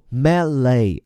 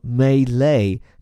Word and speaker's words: melee: 把人拖进这个看上去有点古怪的词 melee，注意它的发音哈，一种呢叫做 melee 啊，重音在前面；另外一种呢发在后面，叫做 melee，这发音倒更符合这个词的来源，就是法语。